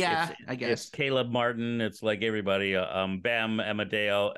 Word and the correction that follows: Adebayo: Adebayo. You can never pronounce that one. – I can never pronounce Adebayo. – Adebayo. – There you go.